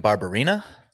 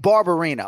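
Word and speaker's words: Barberena: So as of now, this man doesn't have an opponent, but he does have a surname that is mispronounced by a lot of people. So of whom are we speaking? – That is Brian Barberina. – Brian Barberina.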